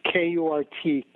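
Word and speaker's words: Kurt Kamm: My name, by the way, is spelled KURTKAMM,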